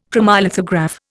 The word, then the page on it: chromolithograph: Indian